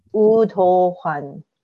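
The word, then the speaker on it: Ooh do hwan (우도환): Ah, so the W is not pronounced. – It is like ooh instead of woo, but sometimes you hear the W if it's like a second syllable because of the transition between the two. So ooh do hwan.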